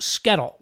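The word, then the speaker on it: SCETL: And the acronym for that is pronounced SKETL, which I think is a great acronym.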